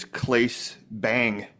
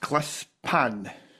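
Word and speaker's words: Claes Bang: Dracula, his name, it looks like when you see it says Clace Bang, Bang is the last name. But apparently it's pronounced Klespan.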